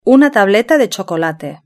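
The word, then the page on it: una tableta de chocolate: Spain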